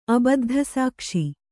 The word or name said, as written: ಅಬದ್ಧಸಾಕ್ಷಿ